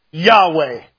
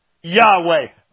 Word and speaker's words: Yahweh: It's spelled YHVH. You say that doesn't, but that's Hebrew. Hebrew has no vowels in its original sense. And they take YHVH and it comes out like this, Yahweh. Yahweh.